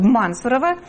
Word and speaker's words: Мансурово: И вся эта история посвящена, это не 5 страниц текста, посвящены скандалам как раз в этом Мансурово. Правильно ударение ставлю? – Да. – В Мансурово.